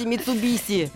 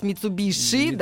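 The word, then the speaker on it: Mitsubishi: Ну, кстати, Митсубиси. Да, пытаются сейчас Митсубиши, да, потому что пишется SH.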